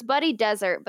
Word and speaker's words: Desert: It says, interesting choice, including spelling, especially considering the second name is apparently pronounced desert. So it's Buddy Desert, but they spelled it with two S's, which is why we can't decide if it says dessert or desert.